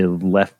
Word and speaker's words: Leffe: Plethora of words and almost 1,000 years of brewing experience behind the Leffe Brewing. Oh, here we go. Lef. Yes, it's pronounced Lef, like L-E-F, Lef.